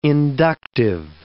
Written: inductive

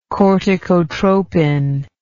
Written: corticotropin

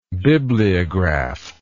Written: bibliograph

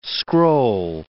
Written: scroll

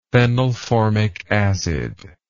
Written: phenylformic acid

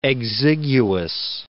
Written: Dionysius Exiguus

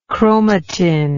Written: chromatoid

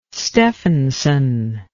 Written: stefansson

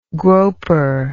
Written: groper

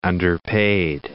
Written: underpaid

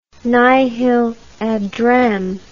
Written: nihil ad rem

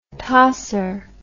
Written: tosser

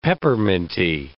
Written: pepperminty

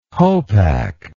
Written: hopak

